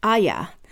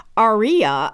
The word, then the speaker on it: Arya: You know, the other thing that Dr. Evans pointed out is that in British English, Arya has two syllables since the R is silent and pronounced Aya. That's how the character pronounces it on the show, and it's apparently how Martin intended it.